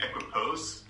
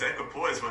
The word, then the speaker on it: equipoise: Apparently, you guys are also mispronouncing it or saying equipose. – I thought it's equipose, yeah. – It's equipoise, what is this? – Oh, is it equipoise? – It's equipoise. I listened to the Google pronunciation.